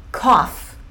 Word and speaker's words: cough: Tosse. É cough. Cough. Com uma sílaba só.